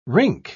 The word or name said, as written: rink